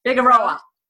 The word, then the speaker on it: Figueroa: Figaro. Did I say it right? – Figueroa. Yeah. – When I read it and then I hear it, I'm like two different things.